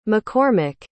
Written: mccormack

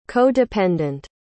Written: co-dependent

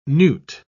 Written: newt

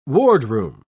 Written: wardroom

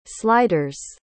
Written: sliders

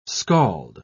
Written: scald

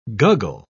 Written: guggle

